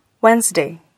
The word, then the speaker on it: Wednesday: Обратите внимание на наличие непроизносимой согласной D в слове Wednesday – среда.